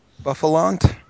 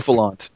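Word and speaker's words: Bouffalant: Buffalant, I think it's pronounced. – Yes, Buffalant.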